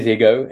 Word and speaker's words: Higgo: I come from a Afrikaans background, but it is Higgo, and you spell it differently. So it is Higgo. So some people explain it hippo, but with an H and with two G's.